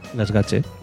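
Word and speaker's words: Gaztelugatxe: Gasgache. – Vale, pues eso. Vale. – El TX es más o menos una... – Es la Che.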